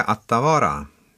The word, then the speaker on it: Attavara: Men det halländska Attavara, det uttalas ungefär så. Ja, inte riktigt så på halländska förstås.